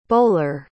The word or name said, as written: boller